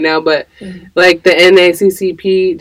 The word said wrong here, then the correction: NAACP: did I say that right? – NAACP.